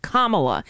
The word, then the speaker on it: Kamala: It's Kamala Harris, like a comma with a la after it. Kamala. It's really easy.